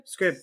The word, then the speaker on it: Scribd: Is it Scribd? Scribd? – Scribd.